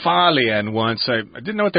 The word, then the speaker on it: Hualien: Hualien is how it's supposed to be pronounced.